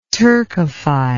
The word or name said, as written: turkophile